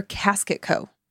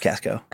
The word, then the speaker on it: Casket Co: Like K-A-S-K, Casco? – The K-E-T is silent. It's just Casco. – I love it when I hear Logan's big belly laugh. – It's spelled Casket Co, but pronounced Casco.